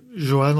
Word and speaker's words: Johan: Johan en français. – Johan, comme ça. – John en anglais. – Oui, John, je sais pas. – Un haut fermé.